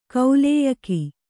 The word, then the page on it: ಕಾಲೇಯಕ: kālēyaka